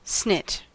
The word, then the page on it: snit: American English